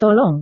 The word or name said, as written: tolong